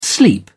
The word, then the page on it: sleep over: British English